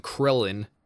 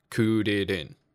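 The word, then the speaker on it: Kuririn: Which is why they never know how, like back in the 90s when they were trying to translate stuff, like they called him Krillin in the anime, mm-hmm. – but in the manga it's spelled C U R R I R-I-N. Kudirin. – Hmm. – Yeah. Because Japanese don't have an L.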